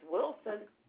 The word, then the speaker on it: Wilson: My maiden name is Wilson. W-I-L-S-O-N.